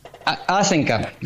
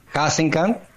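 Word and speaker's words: Hasenkamp: Asenca. – Asenca. Asenca. – Claro. Digamos, la pronunciación original sería de Haxensan, porque sí. Es de origen alemán. Sí. Pero así, coloquialmente, Asenca.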